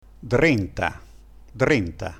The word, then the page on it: drenta: Dialetto